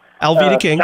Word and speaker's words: Alveda: I'm calling to vote for Alveda King. Did I say that right? – Alveda King.